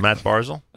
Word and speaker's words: Matt Barzal: A photo autographed by Islander rookie Matt Barzal. Is that the right pronunciation? – That is correct. – Matt Barzal? – Rookie sensation.